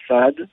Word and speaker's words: S3AD: Et ensuite, un duo toulousain qui s'appelle Saad et qui est plutôt dans un registre musique électronique, expérimentale.